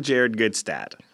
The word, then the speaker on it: Jared Gutstadt: Jingle Jared Gutstadt.